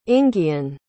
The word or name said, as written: inghean